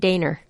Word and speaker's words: Dehner: Is that how you pronounce this? D-E-H-N-E-R? – I tried Dehner. – Dehner. Okay. There you go.